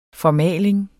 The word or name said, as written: FORMALING